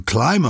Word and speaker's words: climb: You climb a mountain without sounding the B, but you clamber up a mountain pronouncing the B.